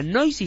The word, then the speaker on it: Noysi: te recomiendo que le eches un ojo a Noisy, se escribe N-O-Y-S-I.